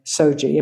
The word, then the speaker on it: SOGI: which goes by SOGI. Am I pronouncing that correctly? – Yeah, that's right.